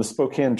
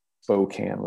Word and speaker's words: Spokane: The Spokane tribe, and actually your pronunciation is perfect, because even though people in the city call it Spokane with a long O, the original word is Spokane, which meant children of the sun.